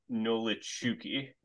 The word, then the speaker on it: Nolichucky: I'm, look, I'm looking it up right now, and it Looks like Nolichuki. Nolichuki.